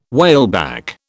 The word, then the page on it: whaleback: British English